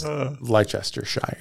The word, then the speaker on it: Leicestershire: but it's Leicestershire.